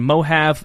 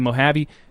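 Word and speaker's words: Mohave: I've been pronouncing Mojave wrong. Apparently, I was saying Mojave. It's actually Mojave.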